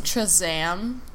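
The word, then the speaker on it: Kazaam: And I think it's actually pronounced Chazam. – Chazam. – Chazam. – Yep, that's it.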